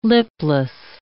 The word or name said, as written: lipless